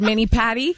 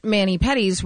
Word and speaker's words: mani-pedi: Actually, he said mani-patty. And I'm like, what's a patty? – By the way, Dave spells it that way, too, mani-patty. – Well, that's the way it's spelled, but... – It's patty. – Oh, no. – P-E-D-I. – Yeah.